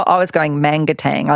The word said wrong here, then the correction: Manangatang: I left out a syllable. It's actually Manangatang.